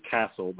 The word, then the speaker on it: CASEL: But one of the earmarks, if you go to the website for CASEL, they pronounce it Castle, but it's C-A-S-E-L.